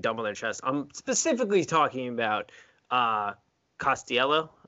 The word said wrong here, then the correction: Costello: Is that how you pronounce his last name? – Kid from Stanford, KJ quarterback. Costello. – Costello. Eh, yeah, there we go.